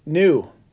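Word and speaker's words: knew: So your word is new. – New? – It's French. – N-E-W. – Oh, that sucks. It's a silent K.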